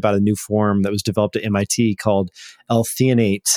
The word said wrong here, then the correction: L-threonate: I think I'm saying that right. Threonate. For brain health.